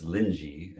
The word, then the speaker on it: Linji: Rinzai, R-I-N-Z-A-I, Rinzai. – Okay, I will look up and educate myself after this talk. – In Chinese, it's Linji, and so sometimes you have to look that up, but we still just honor the Japanese pronunciations of Rinzai.